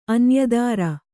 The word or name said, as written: ಅನ್ಯದಾರ